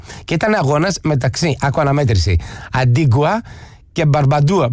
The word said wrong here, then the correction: Μπαρμπούντα: Μπαρμπούντα και Μπαρμπούντα.